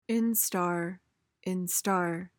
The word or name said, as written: instar